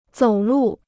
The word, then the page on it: 走路: zǒu lù